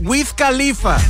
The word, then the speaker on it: Wiz Khalifa: Wiz Khalifa.